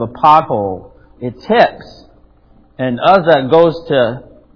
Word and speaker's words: Uzzah: Do you remember Uzzah? I think that's how you say it. Uzzah, U-Z-Z-A-H, 2 Samuel.